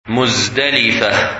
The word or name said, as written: Muzdalifah